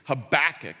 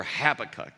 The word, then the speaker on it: Habakkuk: His name is Habakkuk or Habakkuk. You could pronounce his name either way, and you'll probably hear me over the course of this series pronouncing his name both ways.